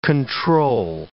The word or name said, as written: control